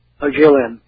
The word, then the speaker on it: Jiaolin: how do you say, is it Jiaolin, G-I-O-L-I-N-N, sir? How do you say that? – Jiaolin, yeah. Jiaolin.